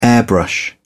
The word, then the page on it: airbrush: British English